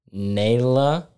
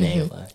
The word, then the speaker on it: Nayla: But yeah, it's pronounced Nayla. I also will respond to Nyla. I will not respond to Nala from like The Lion King, because people have done that, and it gets really annoying after a while, but it's fine.